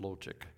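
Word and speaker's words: Lotich: I was struck recently by some wise words written by finance blogger Bob, I think it's pronounced Lochick, comparing God with Tom Brady.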